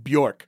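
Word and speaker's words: Björk: Yeah, so I take Bjork, which is somehow one syllable. That's crazy.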